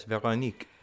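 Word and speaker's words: Véronique: I'm going to assume we pronounce her name Veronica, but it's French. So it's like Veronique. – Yes, Veronique.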